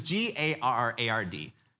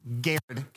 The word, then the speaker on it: Garrard: And this is G A R R A R D. Just shout out, how do you think you pronounce this county? It is Garrard County, Kentucky. Welcome to the South.